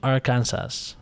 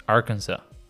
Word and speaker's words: Arkansas: The fifth one, Cancun the sixth pick, Miami, and the last pick of all of them, Arkansas. – No, it's it's pronounced Arkansas. It's true.